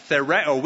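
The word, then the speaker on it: thereo (θεωρέω): The second word is found in verse 6, and it's the word thereo. Sorry, that's how it's pronounced. Thereo.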